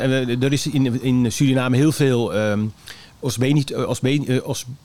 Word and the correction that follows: obesitas: Zeg ik het goed? Obesitas. – Obesitas.